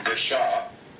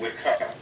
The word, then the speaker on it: Wicca: It's actually even pronounced wrong. It was originally Wicca. – Oh. – But the original practitioners back in the 50s, Gerald Gardner, decided that wasn't right because they were trying to link that word with Celtic rather than Anglo-Saxon, so they started using the hard C. And so it became Wicca.